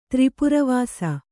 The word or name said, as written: ತ್ರಿಪುರವಾಸ